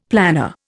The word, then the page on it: planar: Indian